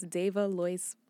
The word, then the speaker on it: Davalois: It's Dava Lois Baron. – Thank you. That's good. One more time. – Okay. For me. Phonetically, it would be Day-V-A, Dava. Mm-hmm. And then, like, Joyce, but Lois. – Yes.